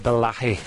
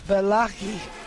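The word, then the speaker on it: Bellaghy: It's pronounced Bellachy. – Bellachy.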